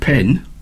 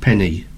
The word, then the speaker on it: penne: Is it, I don't know how you pronounce it, pen? Is it pen? – Penne.